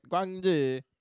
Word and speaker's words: Guangzhou: Guangzhou. – The Glazer one is a, like a factory in Guangzhou, a military factory. – Is how they say it. Guangzhou.